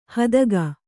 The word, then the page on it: ಹೆತ್ತೇಗ: kannada